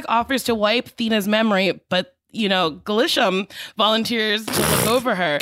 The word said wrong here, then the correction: Gilgamesh: Gilgamesh. Glisham. Gilgamesh. – Gilgamesh volunteers. Honestly, it's spelt the way it's pronounced.